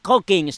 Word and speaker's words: Hawking: Hawking, Stephen Hawking descarta a Dios como creador del universo.